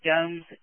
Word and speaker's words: Gomes: Officer, I believe it's pronounced Gomes, G-O-M-E-S.